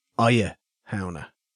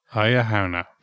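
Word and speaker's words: Oidhe Shamhna: I'd probably, if I had a sword, I'd probably call it Aya Hauna, which is spelt very similar. It's O-I-D-H-E, but it actually means the act of slaying. So instead of Iha Hauna, I'd have Aya Hauna. – Aya Hauna.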